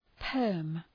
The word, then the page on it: perm: pɜ:rm